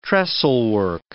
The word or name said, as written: trestlework